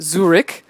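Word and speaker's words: Zürich: We played a show in Zurich. That's in Switzerland. It's spelled with an umlaut, in case you didn't know. Pronounced Zurich.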